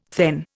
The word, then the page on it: thegn: Indian